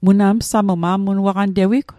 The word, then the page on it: msamu: Mi'gmaq